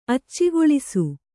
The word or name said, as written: ಅಚ್ಚಿಗೊಳಿಸು